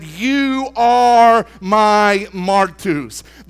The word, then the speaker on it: martus: The word witness, just to break the word down, in the Greek is spelled M-A-R-T-U-S. To pronounce it, we've got our boy on the Strong's Concordance website. – Martus is the name. I can't roll my R's, but it's Martus.